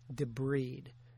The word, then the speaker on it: debride: It is pronounced, by the way, debride. Debride is the woman who walks down the aisle of the church. You don't debride a wound. Maybe if you get divorced, you're being debrided, but it's debridement.